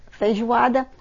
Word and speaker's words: feijoada: The main food there is what we call feijoada. – Spell that, that's feijoada, feijoada.